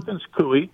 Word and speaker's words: Perkins Coie: Yeah, Perkins Coie.